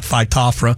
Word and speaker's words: Phytophthora: There's two different ways to pronounce it. I accept both, unlike pecan. Pecan's the only way to pronounce that. All right, but it's, some people say Phytophthora, some people say Phytophthora.